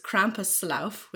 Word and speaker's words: Krampuslauf: And actually in Indiana, they had this Krampuslauf, which is like a Krampus run.